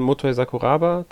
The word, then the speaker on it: Motoi Sakuraba: Von ähm, meistens ja ähm, Motoi Sakuraba dran beteiligt.